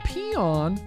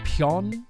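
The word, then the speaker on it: Peön: It's actually pronounced Pion. Like there's supposed to be like a little umlaut over the O there. So it's like Pion. Pion. Pion. I think it's German in origin there.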